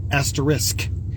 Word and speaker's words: asterisk: And it's funny because Barry and I have both been mispronouncing the asterisk for years. It's actually asterisk.